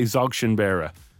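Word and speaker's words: Dzogchen Beara: I don't think the D is pronounced. I'm going to say Dzogchen Bera.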